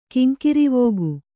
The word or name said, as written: ಕಿಂಕಿರಿವೋಗು